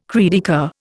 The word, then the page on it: cri de coeur: Indian